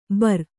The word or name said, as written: ಭೇರಿ